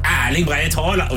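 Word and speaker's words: Erling Braut Haaland: Ja, du tror han forventer at det skal Erling Breit Haaland? You have to say it like that!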